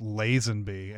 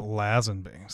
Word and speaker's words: Lazenby: And as all fans know, his name is pronounced Lazenby and not Lazenby.